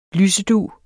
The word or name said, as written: lysedug